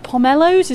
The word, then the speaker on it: pomelo: You can see Anna with her pomelos. Is that how you say it? – Yes, it is.